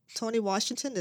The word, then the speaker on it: Tony Washington: Haven't been in the studio for a while, but I am here today with Mr. Tony Washington. Tony Washington, did I say that correctly? – Yes.